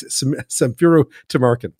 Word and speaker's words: Samfiru Tumarkin: She's an associate specializing in employment law at Tumarkin Mifuru. I'm going to get that name wrong. It's Samfiru Tumarkin.